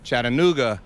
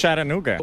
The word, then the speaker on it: Chattanooga: ¿Cómo se pronuncia exactamente? Y lo dicen un jamaicano, un colombiano, pero que vive en Estados Unidos. – ¿Ese nombre? – Chattanooga. – Chattanooga. – En español, Chattanooga. – Es Chattanooga.